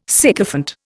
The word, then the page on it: sycophant: Indian